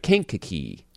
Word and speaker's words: Kankakee: Did I say that right? Kankakee. – Kankakee. Oh, yes.